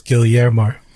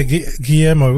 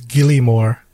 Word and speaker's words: Guillermo: Now, he's from Cook Islands, and growing up, he'd been pronouncing his name as Gillymore, like, because, you know, when you see the word Guillermo, he's like, oh, fuck, I think it says Gillymore.